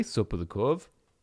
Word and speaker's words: sopa de couve: But so, hey, sopa de couve.